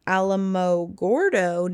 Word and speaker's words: Alamogordo: On July 16, 1945, in a remote desert location near Alamogordo, New Mexico.